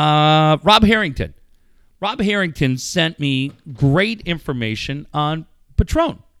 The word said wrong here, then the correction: Patreon: Am I saying that right? Patron's the tequila. Yeah. – No, you're thinking Patreon.